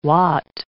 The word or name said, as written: wot